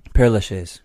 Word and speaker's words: Père Lachaise: Père Lachaise.